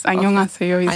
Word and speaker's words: 안녕하세요: 안녕하세요. – Yes, 안녕하세요 okay.